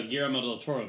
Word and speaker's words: Guillermo del Toro: The Shape of Water was a Guillermo del Toro movie. – No, you're thinking of... – You pronounce that beautifully.